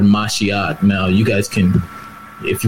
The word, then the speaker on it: Mashiach: So the word Mashiach... as it's pronounced in the Hebrew, which is in the English Messiah.